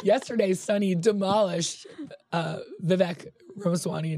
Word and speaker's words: Vivek: And I want to say Vivek, but it rhymes with mistake. Vivek. – Vivek.